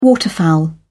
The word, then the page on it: waterfowl: British English